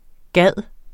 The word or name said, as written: gide